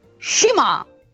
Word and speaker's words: Shima: Fai sentire a Danilo come pronunci il noto lottatore giapponese Cima con la corretta pronuncia, faglielo sentire. – Shima!